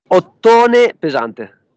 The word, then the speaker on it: Ottone Pesante: All right, Clint from the doorway too, hanging out with, I think it's Ottone Pesante. Is that how you say it? – Ottone Pesante. – I was close.